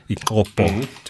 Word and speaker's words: Ikopo: It's set in, I think it's filmed in Ikopo in southern KZN, Ikopo, to pronounce it correctly.